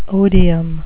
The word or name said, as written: odium